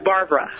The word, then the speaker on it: Barbara: My name is Barbara.